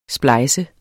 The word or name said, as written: splejset